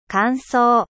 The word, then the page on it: sec: French